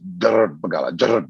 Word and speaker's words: Jarrbikgala: Now, the proper way to pronounce it is Jarrbikgala, Jarrbikgala. You've got to roll your tongue with the R, but Jarrbikgala seems a little bit more easier for white fellows to sort of get their head around.